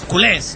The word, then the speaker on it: culés: Con acento en la E. Yo había dicho culés y es culés, ¿no? – Culés.